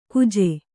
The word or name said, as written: ಕುಜೆ